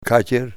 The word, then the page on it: katyir: Ninilchik Russian